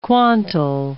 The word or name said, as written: quantal